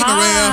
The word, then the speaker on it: Maria: Hey, Maria. – Hi. – Did we get that pronunciation right? – You actually did. Thank you.